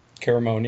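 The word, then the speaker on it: Caramoan: I talked about last week with George on episode 2 about he has a very similar edit to Cochran in Caramon. Is that how you say it? – Caramon, yeah.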